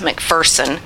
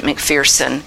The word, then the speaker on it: McPherson: And then, of course, there's Amy Simple my Foursquare friends tell me it's pronounced McPherson, though you've probably heard it McPherson.